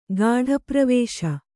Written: ಗಾಢಪ್ರವೇಶ

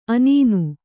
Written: ಅನೂನ

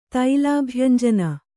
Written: ತೈಲಾಭ್ಯಂಜನ

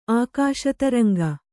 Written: ಆಕಾಶತರಂಗ

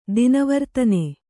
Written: ದಿನವರ್ತನೆ

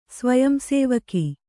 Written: ಸ್ವಯಂಸೇವಿಕೆ

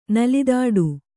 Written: ನೆಲತಾಟಿ

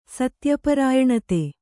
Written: ಸತ್ಯಪರಾಯಣತೆ

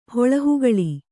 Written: ಹುಲ್ಲುಹಕ್ಕಲು

